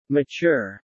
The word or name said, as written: mature